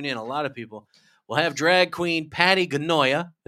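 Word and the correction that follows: Pattie Gonia: Patty Gonya.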